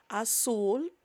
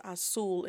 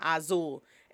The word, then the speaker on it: azul: azul. The way you pronounce blue is azul, is not azul. Every time you see a z, In Spanish, think of it as an S sounds.